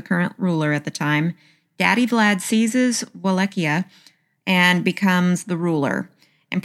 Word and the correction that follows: Wallachia: Am I saying that wrong again? – You're using like an S sound. – Wallachia? – Wallachia.